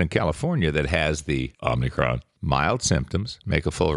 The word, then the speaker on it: Omicron: I want to say Omni. – Omni. – But it's Omicron.